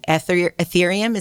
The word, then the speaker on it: Ethereum: Someone named Medicoven had the winning bid and he brought the work in Ethereum.